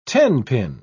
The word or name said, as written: tenpin